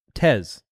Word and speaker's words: Tesla: I thought it, Tez, right? – Yeah. – As though it's a Z. – Yeah. – I believe it is pronounced with a Z, but I also believe that is dumb, and I say it is Tesla.